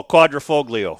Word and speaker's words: Quadrifoglio: It's the Stelvio Quadrofilio? – No, Quadrifoglio.